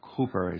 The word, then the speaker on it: Cowper: William Cowper, or Cooper as it's pronounced, who wrote many hymns, he talked about trials as being a time of a frowning providence.